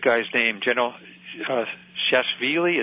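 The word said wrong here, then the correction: Shalikashvili: It's so hard to say. – Shalakashvili. – That one. That's well said.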